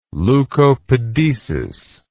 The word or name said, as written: leukopedesis